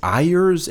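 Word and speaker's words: Ayrer's: It's A-Y-R-E-R-S. – Again, we talked about this before. Germans rarely use the word Y, the letter Y. – Yeah. – So when it's included in a word, it's hard to pronounce. So it's Ayers, Ayers.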